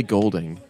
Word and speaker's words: Goulding: It's Goulding, yeah. – Ellie Goulding.